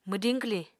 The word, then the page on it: mwdiñ-kwliñ: Apatani